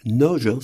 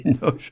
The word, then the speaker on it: Nagy: Michael Noge, c'est un signe qu'on doit dire. Hein. Ouais. Noge, voilà. Ça s'écrit Nagy, mais c'est Noge.